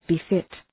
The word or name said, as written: befit